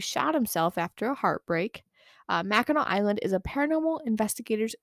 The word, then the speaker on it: Mackinac: Next, we have Mackinac Island itself, which Mackinac. I know. I always pronounced it Mackinac as a kid, and everybody would always look at me weird. I'm like, that's literally how it's spelled.